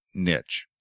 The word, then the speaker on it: niche: And so my version of pronouncing it is, I pronounce it niche.